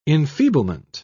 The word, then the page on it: enfeeblement: British English